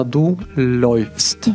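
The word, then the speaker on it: läufst: И это у нас, да, дифтонг, который всегда читается как «ой». И, соответственно, «ду лёйфст».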